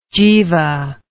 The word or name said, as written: jiva